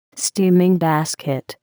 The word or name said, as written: steaming basket